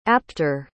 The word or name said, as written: apter